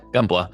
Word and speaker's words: Gunpla: Is that am I saying that right? – Yeah, Gunpla. Mm-hmm.